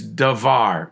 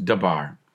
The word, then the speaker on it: davar: For the Hebrew word for cause in this scripture is davar, D-A-V-A-R. It's actually pronounced dabar, which is more correctly translated spoken word.